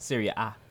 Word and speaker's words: Serie A: Serie A and all is that how you say it? – Serie A. – Oh, Italian.